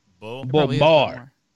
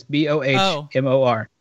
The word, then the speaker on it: Bowmore: Bowmore or Balmore, I think. – Balmore. – Balmore, okay. – It's Bow. – Bowmore. – Bowmore. – Okay. – The pronunciation guide is Bohmor.